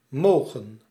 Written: can

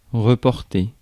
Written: transport

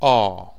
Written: R

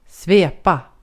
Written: svepa

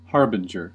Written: harbinger

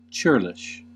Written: churlish

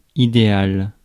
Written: ideal

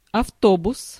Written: coach

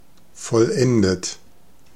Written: perfect

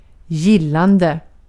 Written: gillande

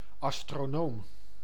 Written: astronomer

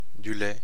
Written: lait

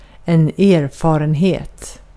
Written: erfarenhet

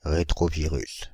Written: rétrovirus

